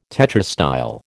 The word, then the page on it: tetrastyle: American English